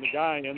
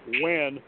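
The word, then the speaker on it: Nguyen: they pronounce his name Nagayan, but that's not how you pronounce it. You pronounce it Wen, almost with the W, with the Stewie W-H, like quip. And so it goes Dango Wen when you actually say his name properly.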